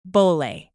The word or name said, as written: bole